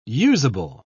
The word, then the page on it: useable: British English